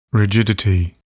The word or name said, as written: rigidity